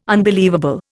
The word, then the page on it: unbelievable: Indian